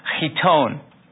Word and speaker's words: chiton: pronounced chiton.